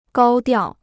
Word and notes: 高调: gāo diào

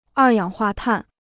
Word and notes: 二氧化碳: èr yǎng huà tàn

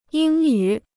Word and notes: 英语: yīng yǔ

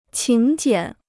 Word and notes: 请柬: qǐng jiǎn

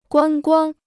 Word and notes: 观光: guān guāng

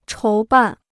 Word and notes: 筹办: chóu bàn

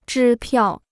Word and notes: 支票: zhī piào

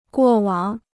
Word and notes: 过往: guò wǎng

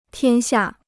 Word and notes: 天下: tiān xià

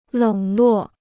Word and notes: 冷落: lěng luò

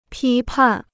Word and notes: 批判: pī pàn

